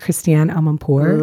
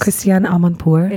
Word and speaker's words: Christiane Amanpour: With Christiane Amanpour. – Love her. Did I say her name right? – Yes. – Christiane Amanpour.